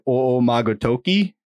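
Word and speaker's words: Omagatoki (逢魔が時): Technically, the O, it has the macron. That should be like two O, should be like Omagatoki.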